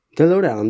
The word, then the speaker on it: DeLorean: E, jak się wymawia tą nazwę samochodu? DeLorean, dobrze. E,